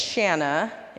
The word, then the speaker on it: Shanna: So, my name is Shanna, it rhymes with banana, and it's one of those names that people see and assume.